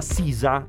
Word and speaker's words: SZA: qui collabora con una giovane artista che si chiama Sisa, è scritto SZA, per pronunciarlo ci vogliono i coglioni buoni.